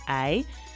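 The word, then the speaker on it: hay: So in this song, we're actually going to learn several different expressions that use the Spanish word I. I. And that's actually spelled H-A-Y, I.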